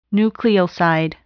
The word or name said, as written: nucleoside